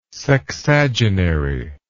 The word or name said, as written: sexagenary